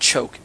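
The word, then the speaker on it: chok (חֹק): It looks like it's spelled choke, C-H-O-K-E, but really it's chok.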